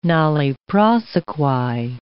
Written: nolle prosequi

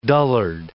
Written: dullard

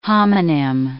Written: homonym

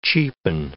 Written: cheapen

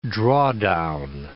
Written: drawdown

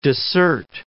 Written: dissert

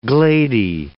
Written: glady

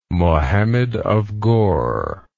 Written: Mohammed of Ghor

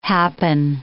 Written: happen

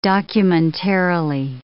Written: documentarily

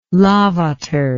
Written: lavater

